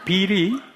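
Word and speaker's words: bill: 우린 빌이 빌 B-I-L-L 하면 혓바닥에 앞니빨 뒤에 와서 빌 해야 하는 이 발음이 없어요.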